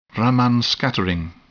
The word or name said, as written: Raman scattering